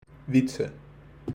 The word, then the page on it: Wietze: wheat + suh